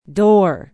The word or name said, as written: Door